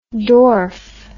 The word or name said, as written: dorf